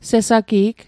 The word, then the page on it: sesagi'g: Mi'gmaq